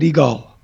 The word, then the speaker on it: Yigal: And so what I came up with, I said, it's like Steven Seagal, but Yigal.